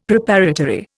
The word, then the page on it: Preparatory: Indian